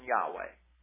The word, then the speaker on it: Yahweh: And it's I-O-U-E or Yahweh or Yahweh. And that's why I use the pronunciation Yahweh.